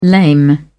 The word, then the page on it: lame: British English